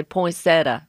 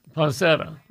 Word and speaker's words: poinsettia: Now, some Southerners just say poinsettia. – Poinsettia. – Mm-hmm. Poinsettia. Leave out just a few letters. – We all have different ways of pronouncing the plant. Poinsettia, poinsettia, poinsettia, depends on where you put the emphasis on that particular word.